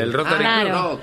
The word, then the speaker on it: Rotary Club: ¿Un rotar? – Un rotar. Eso. – Un perro. – Bueno, eso. – Un rockstar. – El Rotary Club.